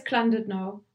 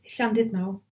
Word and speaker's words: Llandudno: Would an English speaker pronounce it as Llandudno? – Yeah. – Is that the same place? Okay. – Yeah. I mean. Well, Christian says Llandudno sometimes, but it's Llandudno.